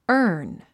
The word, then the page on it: earn: ə’ː r n; アーン